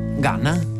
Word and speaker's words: Gunna: Ganna, che yes.